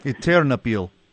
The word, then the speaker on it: Ternopil: He and his teammates thought the city they lived in might be okay, and it was Ternopil, if that's how you pronounce it. – It's Ternopil. – What's that? – Ternopil. – Ternopil, yes.